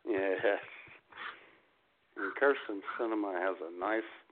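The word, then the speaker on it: Sinema: and they've done their morning update, or mid-morning update, and Kirsten Cinema's, I guess that's how she pronounced her name, Cinema.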